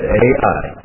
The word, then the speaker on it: Ai: It's spelled just like it said, Ai.